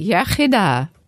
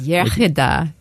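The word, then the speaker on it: iechyd da: You say yechida, good health. – Yechida. – Yeah, the English say yechida, but it's yechida, good health.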